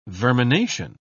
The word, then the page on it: vermination: British English